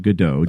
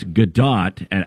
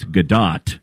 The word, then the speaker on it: Gadot: Now, a lot of people pronounce it Godot. – I thought it was Godot. – But it's Gadot, because I saw an interview where somebody pronounced it Godot, and she says, look, if I was French, it might be Godot, but I'm Israeli. It's Gadot. And when she made it big in the Wonder Woman franchise, that was what really put her on the map.